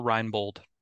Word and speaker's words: Reinbold: Yeah, Rydell Reinbold.